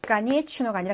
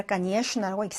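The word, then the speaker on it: 까니에 슈나라고 (конечно): 까니에 추노가 아니라 까니에 슈나라고 읽습니다.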